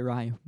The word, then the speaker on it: Ryu: Ryu. – I would say Ryu, but definitely an interesting matchup there.